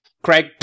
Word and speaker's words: Kreeij: How do you pronounce it? – Craig. – Craig. Please describe who you are and your character, please. – So my character is the Orion Helm Officer Craig. That's spelled K-R-E-E-I-J.